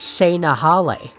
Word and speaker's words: Tsenahale: It is spelled T-S-E-N-A-H-A-L-E, and is pronounced like Se-Nahale,